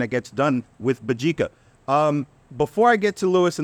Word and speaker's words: Mbadika: If you look at Mbadika, that's Mbadika. The M is silent, so don't doubt yourself. If there's an M, that's Mbadika.